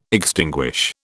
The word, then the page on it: extinguish: British English